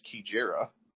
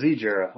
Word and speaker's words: Kijera: I do know from our friend Travis Stone that the 5 is pronounced Kijera. – Oh, I thought it was Zijera. Okay. – So yeah, you wouldn't think, but yeah, like K-E-E-J-E-R-A is kind of how I think of it. – Well, I think G-E-O-F-F is pronounced G-off.